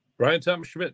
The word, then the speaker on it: Bryan Thomas Schmidt: Bryan Thomas Schmidt.